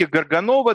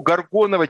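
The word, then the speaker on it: Gorgonowa: Ты совершенно правильно произносишь ее фамилию по-польски, Горганова, да, и фильм называется «Справа Горгановой». Но я буду говорить, наверное, так, как привычнее русскому уху, Горгонова, тем более, что ее фамилия на самом деле не вполне польская тоже, и это очень существенно для этого дела.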